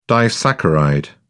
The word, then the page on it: disaccharide: British English